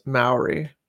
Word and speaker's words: Maori: Oh, Paul told me I mispronounce Maori. It's pronounced Maori.